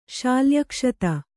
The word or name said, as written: ಶಾಲ್ಯಕ್ಷತ